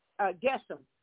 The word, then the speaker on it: Geshem: Sanballat is Sanballat, and it's Tobai, and it's Geshem.